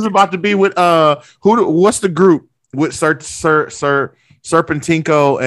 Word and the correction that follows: Serpentico: Serpentico, there we go.